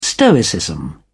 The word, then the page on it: stoicism: British English